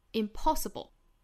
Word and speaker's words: impossible: 那 impossible 这个词它的重读音节也是在后面这个第二个音节上，就是 po 这个音节上 impossible, the impossible,